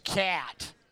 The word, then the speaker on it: kyat: It's actually pronounced cat.